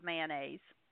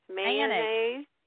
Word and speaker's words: mayonnaise: If you're in the South, you say mayonnaise. There ain't no, it's no three syllables. There does not have three syllables, mayonnaise.